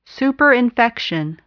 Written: superinfection

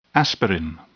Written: aspirin

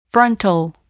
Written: frontal

